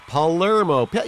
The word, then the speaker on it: Palermo: It's Pelermo. You can't have Pelermo without Palermo. – P-A-L-E-R-M-O.